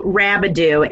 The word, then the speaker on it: Rabideau: Well, I'm excited to be here as well, and it's Michelle Rabideau, and I always say it, you know, pronounce it like Scooby-Doo, and you'll never forget it.